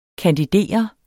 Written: kandidere

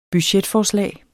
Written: budgetforslag

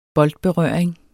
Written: boldberøring

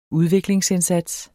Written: udviklingsindsats

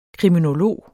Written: kriminolog

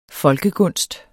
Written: folkegunst

